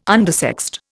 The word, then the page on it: undersexed: Indian